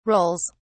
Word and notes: rolls: British English

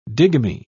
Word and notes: digamy: British English

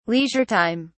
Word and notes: leisure time: British English

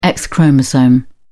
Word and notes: x chromosome: British English

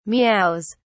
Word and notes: meows: British English

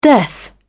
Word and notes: death: British English

